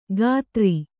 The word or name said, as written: ಖಾತ್ರಿ